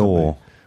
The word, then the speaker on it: gnaw: Gnaw. – Well, it's G-N-A-W. – Yeah, you don't pronounce the G. – And it's pronounced gnaw.